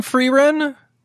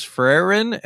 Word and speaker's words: Frieren: I thought it was Frieren, and it is Frieren.